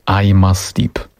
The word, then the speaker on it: I'm Asleep: To już będziemy pewnie wielokrotnie przypominać, tak jak to się powinno wymawiać. Co innego widzisz, co innego słyszysz, wymawia się I'm Asleep.